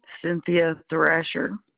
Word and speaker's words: Cynthia Thrasher: Cynthia Thrasher.